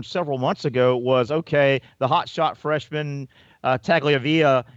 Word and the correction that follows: Tagovailoa: Tua Tagovailoa.